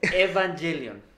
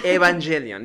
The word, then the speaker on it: Evangelion: Eh, e-, Evangelion. – Evangelion. Perdón, yo soy muy malo para pronunciar. – Evangelion.